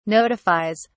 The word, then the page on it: notifies: British English